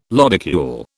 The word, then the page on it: lodicule: British English